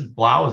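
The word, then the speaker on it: Blough: That was David Blau, I guess is Blau. Is that how you say it? – Yeah.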